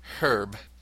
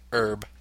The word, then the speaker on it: herb: The H is always pronounced with the word herb, in contrast to the American herb.